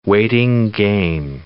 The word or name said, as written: waiting game